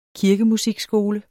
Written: kirkemusikskole

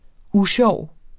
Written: USJOV

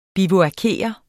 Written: bivuakere

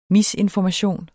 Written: misinformation

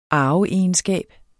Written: arveegenskab

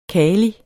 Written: kali

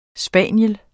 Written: spaniel

